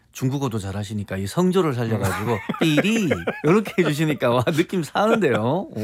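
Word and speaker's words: 삐리 (觱篥): 근데 이 필률을 중국 발음으로 오늘날의 중국 발음으로 하더라도 네. – 삐리예요.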